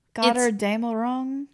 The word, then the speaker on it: Götterdämmerung: It's from the Götterdämmerung. – Oh gosh. – Which is German and I said it wrong. – Yeah. – Götterdämmerung.